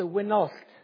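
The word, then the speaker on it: Wenallt: It's W-E-N-A-L-L-T. So it's the Wynnoth.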